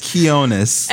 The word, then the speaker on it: Quionez: You say Quionez. It's Quionez.